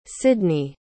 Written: cydney

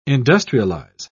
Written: industrialize

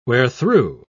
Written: wherethrough

